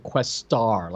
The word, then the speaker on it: Questar: Uh, and they pronounce it Questar. Like they put like a big emphasis on the right, star right. part of it.